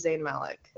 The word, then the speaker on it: Zayn Malik: Okay, that looks like Justin Bieber had a baby with Zayn Malik. Is that how you say his name? – Yeah, yeah, so.